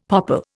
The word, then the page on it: popple: Indian